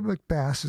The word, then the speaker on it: McBass: It says here, pronounce it like the fish. Okay, Michael McBass is going to prove that Sundays will never be the same.